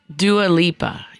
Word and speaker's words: Dua Lipa: Dua Lipa, yeah.